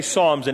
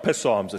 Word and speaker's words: Psalms: And when you say Psalms in English, it's not psalms, it's just Psalms. It's a silent P.